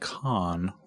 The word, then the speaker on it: Cannes: They're in Cannes, pronounced sometimes like con.